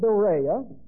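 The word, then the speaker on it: dorea: The word doreia D-O, long O, R-E-A, it means a free gift.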